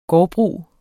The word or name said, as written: gårdbrug